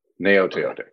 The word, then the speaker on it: Gaoteote: Palaie Gaoteote. It's a tough one. – Right. The G is silent like lasagna. Gaoteote.